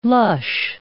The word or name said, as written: lush